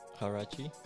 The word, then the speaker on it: Huarache: Huarache? – Yeah, we did this Nike Huarache project.